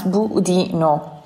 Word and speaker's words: budino: In Italian, we call this budino, pronounced budino.